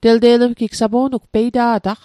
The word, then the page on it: telte'lmatl: tel'te'lmatl; Mi'gmaq